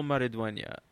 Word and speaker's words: Maridueña: One of their actors, Zolo Maridunya, Maradona. I don't know how to pronounce his last name. It's Hispanic. – Zolo Maridueña.